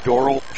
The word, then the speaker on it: Dorrel: Colton Schroeder, 6'3", guard, a senior, and Braylon Dorrell, spelled D-O-R-R-E-L, pronounced Dorrell, junior, 6'6", 13.4 a game.